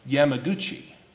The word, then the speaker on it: Yamaguchi: A Japanese man by the name of Yataro Yakabuchi, I think it was. Let me see, how was that? I mispronounced that. Yamaguchi.